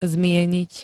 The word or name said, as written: zmieniť